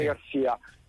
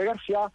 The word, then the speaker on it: Garcia: Allora, ti dico che, vabbè, chiaramente l'origine del cognome è spagnolo, quindi sarebbe Garcia. Alla francese sarebbe Garcia, dopo due mesi che era arrivato, per toglierci lo chiamiamo un giornalista francese, non è che gli facciamo la domanda come si pronuncia, nel parlare di Rudy Garcia ci disse Rudy Garcia.